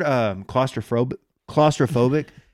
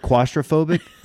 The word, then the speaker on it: claustrophobic: By the way, the word claustrophobic, I've pronounced it quastrophobic for like my entire life. – Did you say quaster? – Quastrophobic. That's how I've always said it.